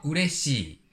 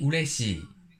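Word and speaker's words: Ureshii (嬉しい): By the way, how do you say I feel so happy in Japanese? – Ureshii. – Ureshii? – Ureshii. – Ureshii. – Ureshii. Yes. That's right.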